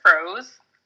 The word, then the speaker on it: Freauxs: So first, I am the founder of Favored Froze. And it's froze, like you say it like F-R-O-S, but it's spelled F-R-E-A-U-X-S, you know, being in New Orleans.